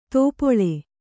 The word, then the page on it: ತೋಪೊಳೆ: tōpoḷe